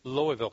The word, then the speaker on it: Louisville: They don't say Louisville. Louisville. It's hard to say. Louisville, Kentucky is how they say it.